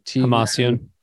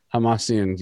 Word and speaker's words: Hamasyan: Hamasyan. – Hamasyan.